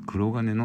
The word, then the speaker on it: 黒鉄: えーこれは国鉄と書いて黒金のサブマリンと、